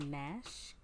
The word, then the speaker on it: Knash: But now apparently they're having this baby, and they're gonna name the baby, I think it's pronounced Nash, K-N-A-S-H.